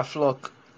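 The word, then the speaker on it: Floki: Is it Floke? Did I pronounce it? – Yeah, Floke.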